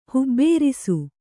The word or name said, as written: ಹುಬ್ಬೇರಿಸು